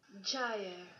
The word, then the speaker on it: Gyre: Gyre.